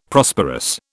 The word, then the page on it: Prosperous: British English